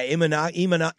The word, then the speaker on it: Imanaga: Uh, how do I say Ima, Imanaga? um, I'll give it to you. – Yeah, Imanaga, Imanaga, I believe is how you say it.